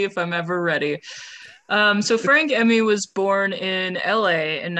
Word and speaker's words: Frank Emi: Listen, you're doing this episode, we're talking about Frank Emmy. I believe that's how I've been pronouncing it. – Yeah, so far as I can tell.